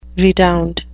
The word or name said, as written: redound